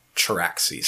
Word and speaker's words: Charaxes: Charaxes. – Charaxes? Yeah. I don't know how to pronounce that, so thank you. It's like C-H-A-R-A-X-E-S.